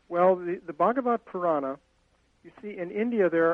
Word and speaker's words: Bhagavata Purana: Am I pronouncing this correctly? If not, I can... – It's easiest to drop the A at the end. – Oh. – Because you can either pronounce it or not, but Bhagavat Purana.